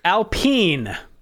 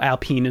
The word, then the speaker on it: Alpine: They've been renamed to Alpine, spelled like Alpine, but pronounced in French.